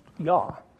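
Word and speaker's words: Yah (יה): But many times in the Bible, it's in abbreviated form, just the first two, Yod, He, pronounced Yah.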